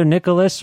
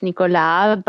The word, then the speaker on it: Nicolas: You can say it either way. I mean, it's written without an H, so it's Nicolas, but um, everybody's, you know, everybody Anglophone says Nicholas, so.